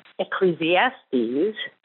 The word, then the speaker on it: Ecclesiastes: Ecclesiastes was written, five syllables, Ecclesiastes was written by King Solomon, and it is within the canonized scriptures. – but I do admire your confidence in correcting Tom. However, it is Ecclesiastes.